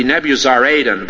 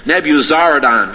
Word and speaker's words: Nebuzaradan: in the our phonetic system, it would be Nebuzaradan, but over in that country, the accent is always on the last syllable, so I imagine, even though he was a Chaldean, it was probably Nebuzaradan, but you want to notice this fellow, whether you call him Nebuzaradan or Nebuzaradan.